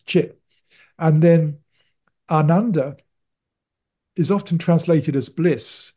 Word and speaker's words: Ananda: It's a long A. So Ananda means deep contentment.